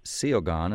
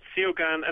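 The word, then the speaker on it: Suo Gân: Well, we're going to go out with the Welsh song, for example, how it looks is spelled Suogan, but you tell me it's pronounced Siogan, and I guess that's what you're told it's pronounced. – Yep, it's Siogan, and it's just really a lullaby to a baby.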